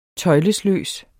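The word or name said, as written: tøjlesløs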